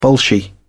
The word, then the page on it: bolshy: British English